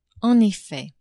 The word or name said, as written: en effet